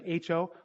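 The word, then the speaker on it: ho (ὁ): So in John 1:1 in the Greek, right before the Word is o logos, and the word for o, and it is pronounced O-H, aho. Or actually, it's like H-O, ho, like ho-ho-ho, Santa Claus.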